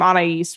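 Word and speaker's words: Anaïs: I know I'm pronouncing the name Anais right because I have several friends with that name with that spelling, but.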